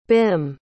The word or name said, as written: bhim